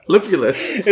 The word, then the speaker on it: Lupulus: I like how you pronounced it correctly though on this first try. Lupulus.